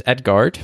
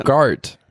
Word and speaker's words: Edgard: You almost say it like Edgar, like the name of a person. – Edgard. – You said it more like Edgar.